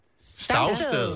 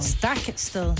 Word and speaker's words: Stagsted: S-T-A-G-S-T-E-D. – Stavsted. – Stavsted, ja. – Det er forkert. – Stagsted. – Det er også forkert. – Stagsted. – Stagsted. Stav, fortæl os det. – Stakste.